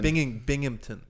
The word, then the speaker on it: Binghamton: Binghamton. – Binghamton.